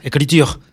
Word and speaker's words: écriture: Escrituras, écriture. – Escriture. ¿Cómo se dice? – Écriture.